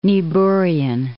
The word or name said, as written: Niebuhrian